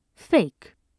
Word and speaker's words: PHEIC: It also allows the WHO to declare a public health emergency of international concern, or PHEIC, pronounced, incredibly, fake, using its own information over the objection of any single country.